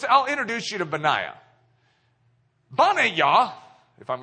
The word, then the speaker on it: Benaiah: But Yah, when you do Bana Yah, that is a name. It's actually a proper name in the Hebrew of one of David's mighty men. Banaya. You probably would pronounce it Benaiah.